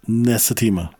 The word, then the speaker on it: Nasitima: Nasitima.